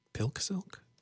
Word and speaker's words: PIQSIQ: That was a group called Pilk Silk. It's spelled P-I-Q-S-I-Q.